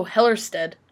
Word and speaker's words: Hellerstedt: So now we're going to go on to whole woman's health versus Hillerotted. I'm so sorry to whoever last name I just butchered. Oh, Hellerstedt. – Wow. – I can't even read my own typing. Hellerstedt. I think that sounds better.